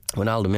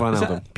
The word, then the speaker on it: Wijnaldum: Wijnaldum, yeah. – Wijnaldum.